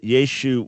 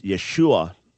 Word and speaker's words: Yeshua: Now, some would argue that Yeshu was the proper pronunciation. We would argue that it's Yeshua, but some argue that that final dropped off at a certain point, hence Yeshu.